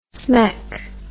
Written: smeck

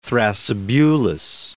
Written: Thrasybulus

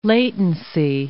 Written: latency

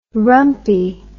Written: rumpie

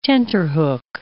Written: tenterhook

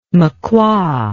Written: makua

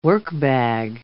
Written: workbag

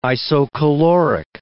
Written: isocaloric

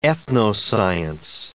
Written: ethnoscience

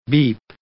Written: beep

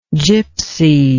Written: gipsy

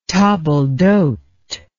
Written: table d'hote

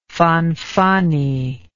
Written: Fanfani

